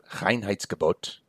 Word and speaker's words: Reinheitsgebot: Now, the Reinheitsgebot, as it's pronounced, it literally means purity order, which I think is just such a wonderful German word.